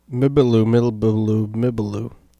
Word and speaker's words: Mbielu-mbielu-mbielu: Mibaloo, Mibaloo, Mibaloo.